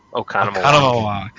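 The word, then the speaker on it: Oconomowoc: I always have to ask, how do you pronounce it? – Economowoc? – Economowoc. The 5 O's.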